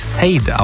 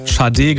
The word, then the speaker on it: Sade: dann würde man vielleicht denken, das spricht sich Sade aus. Der Name kommt aber von der Sängerin, die aus Nigeria kommt. Sie heißt Fola Sade. Sie hat daraus einfach nur Sade gemacht und deswegen heißt auch die Band Sade.